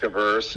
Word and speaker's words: averse: Most of us want to gamble and we want to make money, but what is it, risk adverse, or how do you say that? – Risk averse.